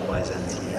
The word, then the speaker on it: Byzantine: I love that word, Byzantine. Just drop the mic on that. – Three syllables, I believe.